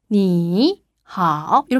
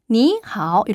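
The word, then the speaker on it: 你好: 그래서 원래는 니하오 이렇게 하면 발음이 힘들어요. 삼성이 원래 자기 혼자 하기도 벅찬 발음이기 때문에 이성 니하오 이렇게 바꾸어 발음해요.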